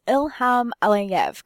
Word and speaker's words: Ilham Aliyev: It's Ilham Aliyev, correct? – That's right. Yes, well pronounced.